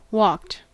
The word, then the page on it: walked: American English